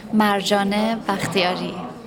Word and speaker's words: Marjane Bakhtiari: För jag, inte ens när jag själv pratar, även om jag uttalar J-et i Marjane.